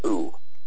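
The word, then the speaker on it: who (הוא): Well, the word he in Hebrew is spelled he, vav, aleph, and it's pronounced who.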